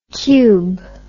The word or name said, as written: qube